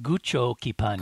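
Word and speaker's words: Gūchokipan'ya: Oh, it's apparently pronounced Gucho Kipanya. – Gucho Kipanya. Okay, sure. – Which is taken from rock, scissors, paper.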